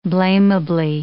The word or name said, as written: blamably